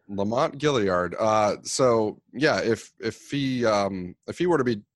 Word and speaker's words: Gaillard: It's Gilead, actually, I think. Lamont Gilead. – Is it? Is it really? Is it Gilead? – Yeah. That's Gilead.